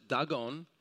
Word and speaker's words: Dagon: It's actually, that's how you pronounce it, Dagon.